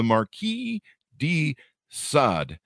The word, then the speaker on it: Marquis de Sade: It came out February 21st, 2023, The Curse of the Marquis de Sade. I believe I had that pronounced correctly. Yeah, there you go.